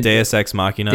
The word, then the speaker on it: Deus Ex Machina: I mean, how about the fucking, how do you say it? Deus Ex Machina? I mean, Deus Ex Machina. – Deus Ex Machina.